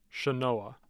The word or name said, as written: chenoa